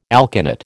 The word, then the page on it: alkanet: American English